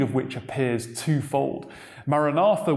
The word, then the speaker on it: Maranatha: Our Lord, come, or O Lord, come, is pronounced from the original text as Maranatha.